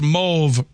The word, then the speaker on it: mauve: The word M-A-U-V-E is pronounced mauve, rhyming with stove in British English, and more commonly mauve, rhyming with the first part of awful in American English.